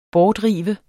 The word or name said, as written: bortrive